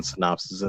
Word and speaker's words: synapses: and really weird looking synapses in the brain. No, synapses? That's how that's pronounced, not synopsis. Synapses.